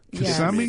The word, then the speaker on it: Kissimmee: Kissimmee? Yes. Kissimmee.